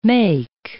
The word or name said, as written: make